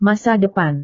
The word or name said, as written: masa depan